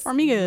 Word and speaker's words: Farmiga: Thaisa Farmiga is Farmiga.